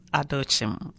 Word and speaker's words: Adochim: My name is Gladys Adochim.